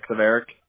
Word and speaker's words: Sverak: Zverik. Yeah, Zverik.